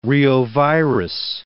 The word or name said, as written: reovirus